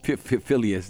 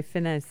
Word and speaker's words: Finneas: Phileas. – Phileas. You see, I can't, like, okay, Phileas. Phileas O'Connell. Honestly, guys, it's spelled F-I-N-N-E-A-S, which would say Phineas, but no, Phileas O'Connell.